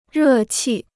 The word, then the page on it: 热气: rè qì